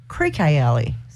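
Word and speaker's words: Creeque Alley: How many times people have actually mispronounced? – Creaky Alley. – It's actually pronounced Creaky Alley.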